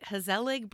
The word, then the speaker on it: Hazelig: Hazelig Brewery? – Nailed it.